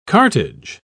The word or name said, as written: cartage